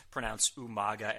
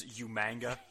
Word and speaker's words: Umaga: Kind of like Regal in the later years when he would always pronounce Umaga as Umanga for some reason.